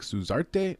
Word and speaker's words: Suzart: At 265 pounds, we've got Waldo Cortez Ocasta from the Dominican Republic versus Danilo Suzart. I think they pronounce it like Suzarte, almost like with a T-E at the end.